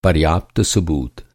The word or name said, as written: सबूत